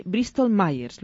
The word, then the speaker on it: Bristol Myers: Es Carmen Vicente, que es Training Manager de Bristol Myers.